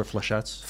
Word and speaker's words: flechette: what is it called, Luca? Flechette? Is that what it called? – That's how I pronounce it.